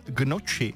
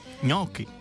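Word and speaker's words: gnocchi: A taky Marťa, ahojda, tak mě tahá za uši, když někdo řekne, že by si dal gnoči. Správně je ňoky.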